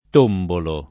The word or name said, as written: tombolare